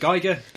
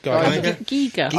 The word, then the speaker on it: Giger: Giger, dead. – Oh. – Is it Giger? Geiger? – Giger. – I think it's Giger. – I know it's not Geiger. – Ah. As in counter, yeah.